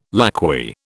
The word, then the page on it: lacquey: British English